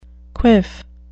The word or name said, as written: quiff